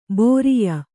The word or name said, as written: ಬರಿಯ